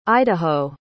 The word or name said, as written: idaho